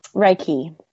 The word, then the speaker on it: Reiki: about how do you pronounce it? Reiki? – Reiki. – Reiki, because I've yeah. heard Reiki. I've heard it, it, mentioned any number of ways, and I want to say it properly.